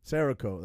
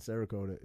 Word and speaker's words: Cerakote: How's it pronounced? – Cerakote. – That's it. Cerakote.